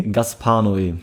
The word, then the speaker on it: Gaspar Noé: Gaspar Noé? – Gaspar Noé.